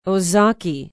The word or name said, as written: ozaukee